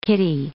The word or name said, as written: kitty